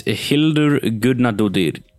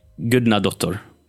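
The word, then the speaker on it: Hildur Guðnadóttir: So it's Hildur Gudnadodir. Gudnadottir. So so. – So that's a D. Huh. – Uh, no, it's not a D because the D is right there.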